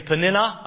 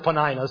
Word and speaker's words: Peninnah: Now you can say Peninnah, I've heard it pronounced that way, but back when I knew her, back in that day, you know, when I was around back then, we all knew her as Peninnah, so I'm going to simply say Peninnah because that's the way it rolls off my tongue.